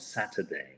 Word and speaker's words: Saturday: Así que podríamos decir que lo podemos pronunciar sin la R, como Saturday, ¿eh? Saturday. No pronunciar ese Saturday, ¿eh? No ese Saturday, sino nos olvidamos de la R como si no existiera, ¿eh?